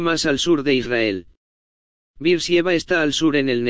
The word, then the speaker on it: Beersheba: Luego solo queda una ciudad más y creo que la pusimos en un círculo y esa es Beersheba, suena como Beersheba. Beersheba está aquí abajo. Dice cerveza. ¿Alguien tiene? ¿Alguna vez oíste decirlo como Beersheba? Beersheba, eso era cuando yo era más joven, así es como fue pronunciado.